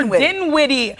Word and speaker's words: Dinwiddie: Spencer Dinwiddie.